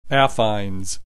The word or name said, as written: affines